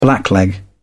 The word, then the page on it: blackleg: British English